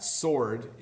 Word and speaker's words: sword: I just put this one in here because I thought it would be fun to talk about that sword is pronounced sword, even though it has a W in it.